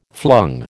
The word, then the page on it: flung: American English